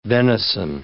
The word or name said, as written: venison